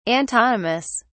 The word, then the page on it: antonymous: British English